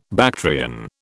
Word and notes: Bactrian: British English

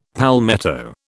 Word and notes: palmetto: British English

